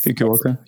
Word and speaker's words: Fukuoka: They're going in December to, oh my God, I'll say it wrong, Fukuoka in Japan. – Mm-hmm, mm-hmm. – That's Fukuoka.